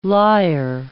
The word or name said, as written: liar